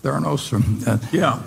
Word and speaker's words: Theranos: And Theranos, I think, is that the way you pronounce it, Charlie? Theranos? – Yeah.